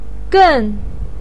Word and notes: gen: 4

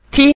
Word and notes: ti: ti5

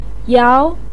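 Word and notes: yao: tone 2

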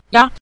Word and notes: ya: ya5

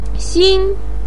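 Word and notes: xin: tone 1

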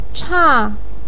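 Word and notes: cha4: tone 4

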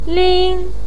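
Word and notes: ling1: pinyin; tone 1

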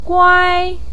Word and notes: guai: 1

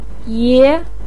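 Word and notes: ye: tone 2

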